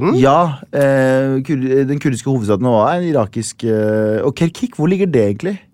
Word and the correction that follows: Kirkuk: Kirkuk, på Kirkuk.